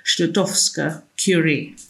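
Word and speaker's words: Skłodowska-Curie: But she would be Marie Stodowska-Curie. – I think that's pretty good. I was on YouTube doing one of those how do you pronounce names things, and I think how you just did it was pretty much it.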